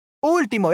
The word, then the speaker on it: último: Último, yeah, último, yeah. So último, the last, uh, doesn't have the tilde at the last syllable, but rather at the start or in the third to last.